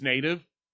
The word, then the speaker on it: Nātv: The name of the restaurant is Native. It's spelled N-A-T-V.